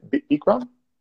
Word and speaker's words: Bikram: Bikram? – Bikram.